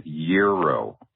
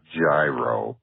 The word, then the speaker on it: gyro: And finally, it's euro, like 365 degrees. Euro not gyro, like a gyroscope, gyro, and tzatziki.